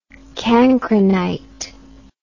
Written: cancrinite

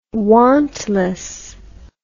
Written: wantless

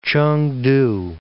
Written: Ch'eng-tu

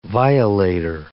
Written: violator

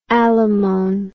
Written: allomone

